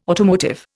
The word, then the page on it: automotive: Indian